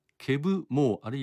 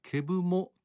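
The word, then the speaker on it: Keb' Mo': そしてこの歌をカバーしているのがケブ・モー、あるいはケブ・モと発音するかもしれません。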